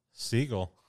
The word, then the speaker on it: Siegel: It's Katie Seagal? – Siegel. Seagull, spelled like Seagull, the bird.